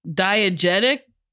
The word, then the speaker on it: diegetic: Well, I'm glad you had that one, because diegetic? Oh, did I say it right? – Yeah.